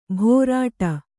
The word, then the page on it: ಭೋರಾಟ: bhōrāṭa